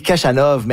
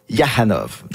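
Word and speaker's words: Khachanov: Là, ça dit Kachanov, mais lui, il prononce Yahanov.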